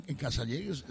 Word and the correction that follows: Cazalegas: Serán Cazalegas. – Sí, pero yo lo pronuncio así. – Claro. – No, es que para entender hay que saber inglés. – Es que es la Mariano. Es un pijo. – Casaliguis. Cazalegas, coño.